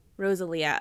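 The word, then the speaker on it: Rosalía: I think it's Rosalia. – Rosalia. Okay. She's Spanish, and she is a singer-songwriter who's actually quite well-known.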